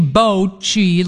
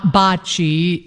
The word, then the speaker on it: bocce: They said it should be bocce, like bowling, not bocce, and every Italian there.